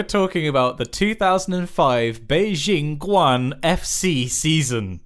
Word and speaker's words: Guoan: It definitely sounds like Guoan. It's spelled G-U-O-A-N. Guoan. – Go on.